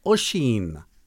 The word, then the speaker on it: Oisín: Yeah, so it's kind of somewhere in between the two, Oisin and ocean. So Oisin. – That's interesting because it has that O-I at the beginning. So the first I is silent, Oisin.